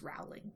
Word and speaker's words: Rowling: I remember it is pronounced Rowling.